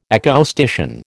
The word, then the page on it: acoustician: American English